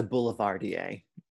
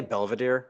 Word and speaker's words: Boulevardier: It's a Boulevardier. – Oh, what did I say? Belvedere? – Yes.